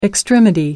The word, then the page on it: extremity: American English